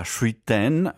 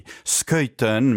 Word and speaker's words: Schuiten: Le Cité Obscure è una celebre serie di bande dessinée firmata da François Schuiten, anzi Schuiten, mi dicono di pronunciare, me l'hanno anche scritto, c'è qui Iari Bernasconi che mi guarda severamente e ha ragione, e da Benoît Peters.